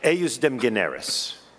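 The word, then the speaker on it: ejusdem generis: The other option is to follow the canon which I will pronounce, although I don't think there is a fixed pronunciation, Eusdem Generis, spelled Justum Generis, maybe. That's probably other people pronounce it that way.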